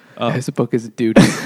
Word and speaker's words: Dodie: Is it Dodie Smith? – I thought it was Dodie. – It's Dodie. Well played. It should be Dodie.